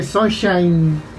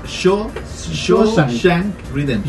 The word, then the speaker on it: Shawshank Redemption: Bueno, no, cuando yo dije Sunshine. Siempre la pronuncio mal, pues son, yo digo, es son, es Shawshank Redemption.